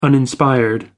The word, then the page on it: uninspired: American English